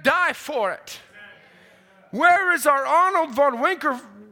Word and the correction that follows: Winkler: Wink, Winkler, I believe is how it's actually pronounced, again today?